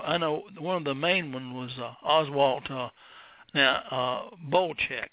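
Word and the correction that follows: Boelcke: The guys at Top Gun who adore him call him Oswald Bolke.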